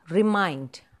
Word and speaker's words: remind: So, that is a word, remind. Mind, the second syllable is stressed. Two syllables, remind. And mind is stressed.